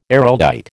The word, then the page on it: Araldite: American English